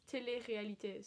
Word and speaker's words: télé-réalité: It's télé-réalité.